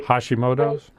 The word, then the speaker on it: Hashimoto's: Yeah, and it's the Shimoto disease is with it. I can't pronounce it correctly. – Hashimoto's? – Yes, mm-hmm.